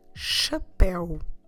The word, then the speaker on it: chapéu: C-H-A-P-E. Com acento U. So we have an accent there on the E, and that will open the vowel, chapéu, and stress where the accent is, as always. Chapéu, that means hat.